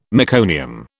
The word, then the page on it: meconium: British English